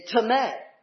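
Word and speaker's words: tome (τιμή): It's spelled that way. T-I-M-E, but in Greek. But in Greek, it is pronunciated tome.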